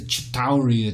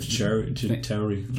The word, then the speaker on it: Chitauri: And the Chitauri attacked New York. – That was a nice pronunciation of Chitauri.